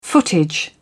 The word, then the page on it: footage: British English